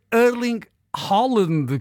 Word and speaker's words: Erling Haaland: E um look novo de Erling Haaland, como se diz em norueguês.